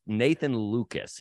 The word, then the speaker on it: Nathan Lukes: So to start with, and we both know this and we both have messed it up either way, but his name is pronounced Nathan Lucas, even though it is spelled Lukes.